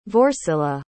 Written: vorsila

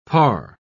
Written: parr

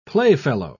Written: playfellow

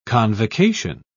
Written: convocation

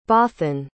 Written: Bothan

